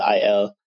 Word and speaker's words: Aiel: Like he's talking about the Aiel. Um, I call them ales my entire life, but apparently that's how you say it.